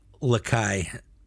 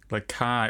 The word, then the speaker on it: Lakai: James's last name is pronounced Lakai. – Lakai. Oh, like Lagai. Like Lagai, but with a C.